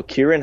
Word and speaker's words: Ciaran: I think he's, I think he's Irish, and I think they pronounce a C as a K. Uh, okay. – So Ciaran Hinds has been cast as Steppenwolf in Justice League.